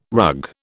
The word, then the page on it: rug: British English